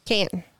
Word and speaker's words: Canton: But there's so many people that go to the Canton flea market. Am I saying that correctly? Because people make fun of me all the time. I say Canton. Canton. Canton. – Canton. Yeah.